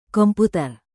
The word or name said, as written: komputer